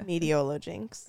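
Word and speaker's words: Meteolojinx: Mediolo Jinx. – It's like meteorology. – Like meteorologist. Yeah, that makes sense.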